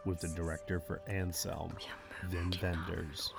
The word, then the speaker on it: Wim Wenders: Welcome everyone to the Next Best Picture podcast, where we are talking with Wim Wenders, the legendary director of the new film Anselm.